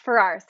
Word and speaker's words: Farrar: Yes, Farrar. So like Ferrari, but without the little E at the end.